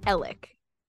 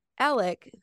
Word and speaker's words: Alex: I don't know if that's how the name Alex is typically pronounced down there or if that's just how he pronounces it. But every media source calls him Alec Murdaugh.